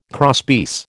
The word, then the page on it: crosspiece: American English